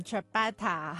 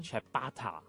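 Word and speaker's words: ciabatta: 啱啱我哋仲专门查咗下佢嘅发音嘅。 – 嗯，Chabata 系啦，Chabata 啊，咁啊，究竟呢个 Chabata 咧，点解会令到当下年轻人咧为之而疯狂咧？吓，稍后会倾倾嘅。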